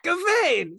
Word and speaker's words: Gawain: It was Gawain? – Gawain, yeah.